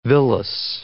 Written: villous

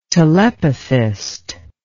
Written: telepathist

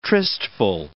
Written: tristful

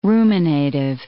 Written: ruminative

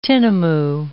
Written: tinamou

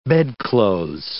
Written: bedclothes

